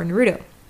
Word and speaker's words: Naruto: Anyway, so we'll talk about the word Naruto has three mora. Now, there's another rule in Tokyo Japanese that says that the first mora of the word is always low, unless the word's accent is on the first mora, which I'm positing is the case for Naruto.